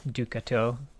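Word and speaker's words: Du Couteau: So Cass is she is born to the house of the Noxian royal house of Ducato. Did I say that right? – That's always how I pronounced it. Ducato.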